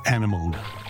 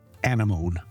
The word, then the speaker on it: anemone: As a boy in 1970, working on his Jacques Cousteau Life of the Ocean sticker collection at the kitchen table, I pronounced the exotic creature as a sea anemone. Years later, it was Sheila who set me right. It's sea anemone.